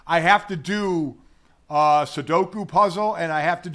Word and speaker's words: Sudoku: And is it I always say it wrong. Sudoku? Is that what? – Sudoku, yeah.